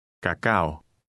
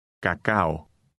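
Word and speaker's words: Cacao (κακάο): Number 12 ends with a combination of the Greek A and O. Try to read it. – Cacao. Cacao. – When pronounced together, these two letters sound like the end of the English word cow.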